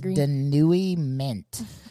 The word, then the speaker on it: denouement: I get it, but that's not how it looks, and I don't like that. – No, it's pronounced denouement.